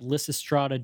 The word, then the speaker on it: Lysistrata: And this would be, I don't know if I'm pronouncing this correctly, but Lysistrata Jones. – How did you know that? – Well, I know the, I mean, it's an old Greek play. And I actually did a Lysistrata.